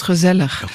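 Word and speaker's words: gezellig: Qu'est-ce que c'est que ce fameux Roseleur qu'on écrit en français G-E-Z-E-2-L-I-I-G? – Oui, ça se prononce Roseleur.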